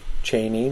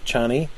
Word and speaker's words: Chani: And then we switch back to Paul, and he's just been given the food by Chaney, and I think it's a long A, Chaney, not Chani.